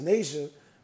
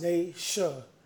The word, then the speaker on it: Nasia: I can read her name as Nasia, but she said it's pronounced Nasha. Like, come on, it's N-A-S-I-A.